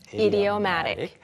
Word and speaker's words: idiomatic: 여기서 사리나 씨의 발음은 조금 다르게 들렸지만 원래 표준 발음은 idiomatic.